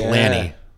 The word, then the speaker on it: Lanny: Landy McDonald. – Landy McDonald. – I don't know if we're butchering his first name. Oh, God, yeah. It's Lanny. L-A-N-N-Y, Lanny.